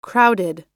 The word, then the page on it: crowded: American English